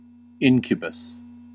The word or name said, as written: incubus